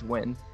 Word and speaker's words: Nguyen: That's like the time when I was like Nguyen, Nguyen, and Bill Castellani's like, it's Wynn.